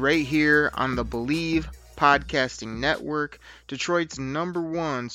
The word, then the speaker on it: BLEAV: We're going to talk all about the Detroit Lions and make sure that you believe as we head into 2020, the NFL draft, free agency, all types of things.